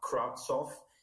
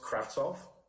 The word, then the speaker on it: Kravtsov: It's not Kravtsov. Well, it's pronounced Kravtsov. And when I hear people say Kravtsov, that just annoys me. – It's not a hard T. There's no T. – Well, there is, but the problem is people pronounce the S before the T.